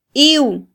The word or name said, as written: iw